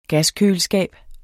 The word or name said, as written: gaskøleskab